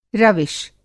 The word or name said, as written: रविश